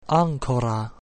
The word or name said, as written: ancora